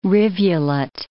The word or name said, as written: rivulet